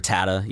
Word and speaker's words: Rattata: Rattata, is that how you pronounce Rattata? – It? Rattata, yeah.